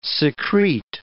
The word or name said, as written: secrete